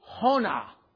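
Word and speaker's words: Yonah: The word, starting in the first verse, chapter 1, the book of, how they say it in the Hebrew is Hona.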